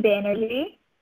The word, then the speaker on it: Banerjee: My name is Shoily Banerjee.